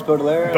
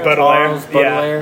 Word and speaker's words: Baudelaire: Charles Baudelaire. – Baudelaire.